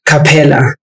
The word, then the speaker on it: Kapela: Kapela. Mm. So without the H. – Yeah, there's no H.